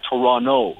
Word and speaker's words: Toronto: We don't pronounce the second T. – Hold the fuck on, man. Wait a second. You just threw me for a loop. I can't say Toronto. – Nope. No. The second T is silent.